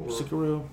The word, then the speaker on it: Sekiro: Sekiro, okay.